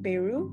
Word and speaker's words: Beirut: Beirut? – Yeah, you are.